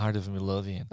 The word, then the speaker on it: Heart of Midlothian: Heart of Midlothian.